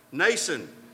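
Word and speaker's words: Mnason: This guy's name, the M is silent here, like the X in cucumber. Nason, and we don't really know anything about Nason.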